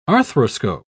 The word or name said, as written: arthroscope